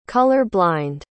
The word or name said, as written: color-blind